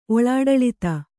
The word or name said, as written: ಒಳಾಡಳಿತ